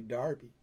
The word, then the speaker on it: Derby: Uh, Golden Ghost from England, from well, it's spelled like Derby, but they say Darby, like Darby Crash, but with e.